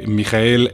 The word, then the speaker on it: Michael: Pues el autor de tamaña obra es nada más y nada menos que eh, Michael Ende, o Michael Ende, ¿no? Digo, si a Michael Schumacher le dicen Michael y se escribe Michael y lo leemos.